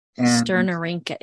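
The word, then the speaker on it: Sternorrhyncha: Sternorhynchus. It's Rinka, not Ranka.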